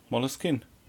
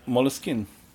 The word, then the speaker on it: Moleskine: Moleskine. – Also ich würde. Du hast aber doch die Betonung bisschen hinten auf dem Kien, ne? – Ja, Moleskine.